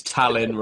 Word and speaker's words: Tallinn: And Tullin is... T-A-L-L-I-N. – It's Tallinn, Ross. – Tallinn, oh.